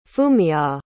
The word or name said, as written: fumiya